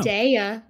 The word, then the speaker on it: Zendaya: Zendaya? You think Zendaya? – So? – Zendaya. There was a whole song that taught you how to say her name.